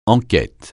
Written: enquête

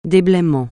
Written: déblaiement

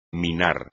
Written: minar

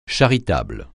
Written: charitable

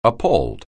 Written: appall

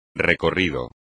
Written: recorrido

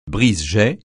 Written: brise-jet

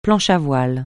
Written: planche à voile

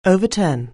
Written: overturn

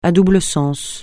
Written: à double sens

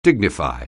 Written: dignify

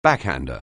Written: backhander

